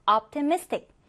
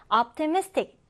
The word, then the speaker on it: optimistic: 이제 이 단어 강세 주의해서요. optimistic, optimistic 이렇게 발음해 줍니다.